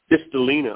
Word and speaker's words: Kristalina: K-R-I-S-T-A. L-I-N-A, Crystalina. – Crystalina, okay.